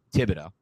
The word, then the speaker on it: Thibodeau: Neil Hutchinson or Thibodeau, did I pronounce it right that time? – Yep.